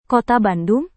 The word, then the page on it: kota bandung: indonesian